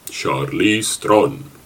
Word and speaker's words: Charlize Theron: Well, welcome by Charlize Theron.